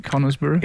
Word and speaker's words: Conisborough: Is that how you say it? Conisborough? – Yeah.